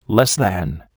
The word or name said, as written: less than